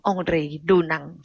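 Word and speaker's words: Henri Dunant: เมื่อกี้ที่ฉันอ่านคำว่าองรีดูนังคนผักดันน่ะชื่อองรีดูนังแต่ถนนในบ้านเราเนี่ยอ่านว่าอังรีดูนังนะคะต่อมานะคะพอมีถนนสมรามาเนี่ยนะคะในปีพ.ศ.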